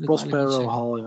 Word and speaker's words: Prospero Hall: En het spel Horrified wordt uitgegeven door Ravensburger en is bedacht door Prospero Hall. Zeg ik dat goed? Of mag ik Prospero Hall, ja.